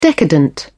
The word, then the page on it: decadent: British English